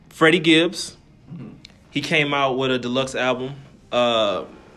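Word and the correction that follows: piñata: I think I'm saying that right. Or Pin, well, I think it's Panada. – It's Pinata. – Thought so. Okay. Because they had the, yeah, the little squiggly line. Accent.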